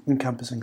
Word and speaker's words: encompassing: Encompassing. – Encompassing, thank you. – Okay. – Wrong inflection onto the wrong syllable.